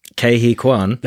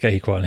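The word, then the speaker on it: Ke Huy Quan: Kehi Kwan. – That's it, Kehi Kwan.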